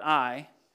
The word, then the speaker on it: Ai: Joshua sent men from Jericho to Ai, and it's spelled Ai, but it's pronounced Ai, which is near Beth Aven, east of Bethel, and said to them, "Go up and spy out the land."